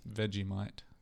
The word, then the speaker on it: Vegemite: Vegemite.